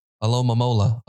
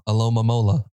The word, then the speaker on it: Alomomola: Why can't people get this? It's very easy. I often find myself just reciting it over and over. Alomomola, Aloma, Alomomola, Alomomola. Very easy.